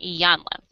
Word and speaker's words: Iyanla: And Ivana. I knew I was saying her name wrong. Iyanla.